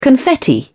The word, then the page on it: confetti: British English